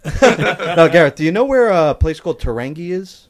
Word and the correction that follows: Tūrangi: Can you spell it for me? – T U R A N G I. – No. Tūrangi. – Is that it? – Tūrangi. Yep.